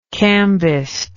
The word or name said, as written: cambistry